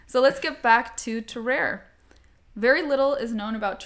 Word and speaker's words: Tarrare: And it's possible that our subject was given this nickname of Tarrare in reference to his very intense flatulence. – I love the way you phrased that. Also, that French pronunciation, like, boom.